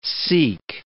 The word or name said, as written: seek